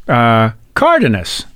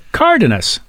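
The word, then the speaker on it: Cárdenas: Cardenas. Yeah. See, accent over the A? Cardenas. So you and I would pronounce it Cardenas, but the accent is over the A.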